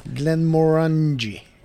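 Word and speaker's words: Glenmorangie: Ja, jag skulle nog sagt något annat. Men ja. Vi tar ditt uttal där. – Glenn Morangi.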